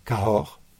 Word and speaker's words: Cahors: The vineyard is very close to a town in France called Cahors.